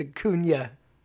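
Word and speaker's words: Acuña: And he's like, learn how to say his name. It's Acuna.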